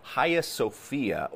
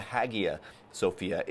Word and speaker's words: Hagia Sophia: It's called the Hagia Sophia, or you may have heard it pronounced the Hagia Sophia.